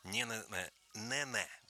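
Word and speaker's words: Neneh: Допустим, есть такая прекрасная певица Нэнэ, вот так вот, если правильно говорить. У нас ее почему-то Нана называли. Нэнэ Черри.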